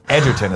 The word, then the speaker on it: Egerton: Edgerton is how it's pronounced, says Spaceman 01.